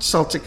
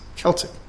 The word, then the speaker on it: Celtic: That's not Celtic, it's Celtic. And how could I forget that? Sorry for mispronouncing it for many years, but it's Celtic Christians.